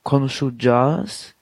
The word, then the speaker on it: konuşacağız: Und dann kommt am Ende noch Konuşacağız, dieses G mit diesem Bogen drauf, das ist ein weiches G, wie man sagt. Aber das müsst ihr nicht so beachten, da könnt ihr auch komische Jazz sagen, also sehr flach ausgesprochen.